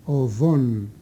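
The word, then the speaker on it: ὀϝόν: Είχε ένα δίγαμα το οποίο προφερόταν σαν β, οβόν, και το οποίο πολύ νωρίς χάθηκε, δηλαδή έπαψε να προφέρεται, συγήθηκε, όπως λέμε, στη γλωσσολογία. Έτσι το οβόν έγινε οόν. Οβόν, περίπου σαν βου είναι εκείνο το δίγαμα.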